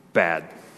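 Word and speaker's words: Bad (בד): You know how the Hebrew word for alone is pronounced? Bad. That's how it's pronounced.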